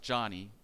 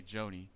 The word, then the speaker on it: Joni: That person on the left, I believe it's pronounced Johnny. I used to always say Joni, but Johnny Erickson Tata.